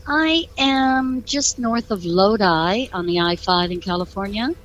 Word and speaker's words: Lodi: Well, I'm glad you said that right, because you know what I used to call that? I used to call it Lodi until someone corrected me.